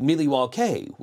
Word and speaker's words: Milwaukee: So I'm just like, you know, yes, the Indians actually pronounced it Miliwauke, which is Algonquin for the good land.